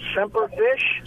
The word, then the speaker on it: Semper Fish: Called Semper Fish. – Spell it. – S-E-M-P-E-R. – Okay. – Like Semper Fi, only right. Semper Fish.